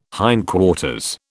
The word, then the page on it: hindquarters: British English